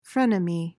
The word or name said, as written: frenemy